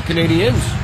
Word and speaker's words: Canadiens: whose father played for the Montreal Canadiens. – I like your pronunciation of Canadiens, too. – Is that accurate? – Yes. Gives you real street cred here.